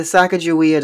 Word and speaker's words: Sacagawea: sakawajia is that how you say her name? Pocahontas. – Yeah, the Sacagawea Dollars.